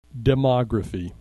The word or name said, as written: demography